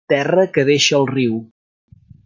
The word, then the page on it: Terra: Catalan